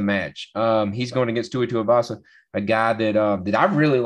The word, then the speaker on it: Tuivasa: He's the favorite in this one at minus 190 going against Tua Tuivasa, right? Plus 160. I'm pronouncing that correct? – Awesome, yes.